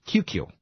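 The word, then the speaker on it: QCue: Now, Kahn, this guy Barry Kahn, he's got a company pronounced QQ. It's the big letter Q, little letter C-U-E. QQ.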